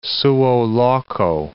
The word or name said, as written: suo loco